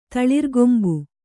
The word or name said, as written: ತಳಿರ್ಗೊಂಬು